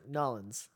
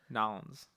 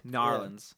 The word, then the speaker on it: New Orleans: They don't say it right. It's Nollins. – Nollins.